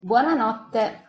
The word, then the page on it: buonanotte: Italian